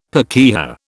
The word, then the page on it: pakeha: British English